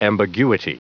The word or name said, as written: ambiguity